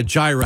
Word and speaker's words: gyro: Is it a gyro or a gyro? What's the right way to say that? A gyro? – Gyro. Yeah.